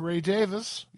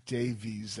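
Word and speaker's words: Ray Davies: Open that set with Ray Davis. Yeah, Ray Davis. I know Americans say Davies. That's not how it's pronounced.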